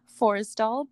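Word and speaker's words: Forestdahl: Like, so for the Bluebird app, it is also Forestall, but it is for the Bluebird. – It's spelled Forest and then D-A-H-L, Forestall.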